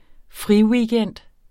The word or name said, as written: friweekend